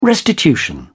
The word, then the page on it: restitution: British English